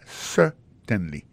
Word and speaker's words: certainly: Alors, en parlant de accent, gardez bien pour un mot certainly, accent tombé sur le premier syllabe là. Yes, certainly.